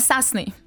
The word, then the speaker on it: сасный: Следующее слово «сасный». Сасный. Ударение на «а». Происходит от английского «саси», ну, «сейси», короче, через «эй» пишется.